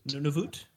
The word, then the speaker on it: Nunavut: Nunavut or Nanavut? – Nunavut. – Nunavut.